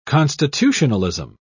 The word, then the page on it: constitutionalism: British English